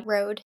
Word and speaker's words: Rhode: Rhode. Yeah, Rhode.